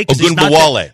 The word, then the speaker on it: Ogunbowale: Ogunbowale.